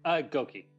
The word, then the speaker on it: Gokee: Am I pronouncing that right? – Gokee, yeah.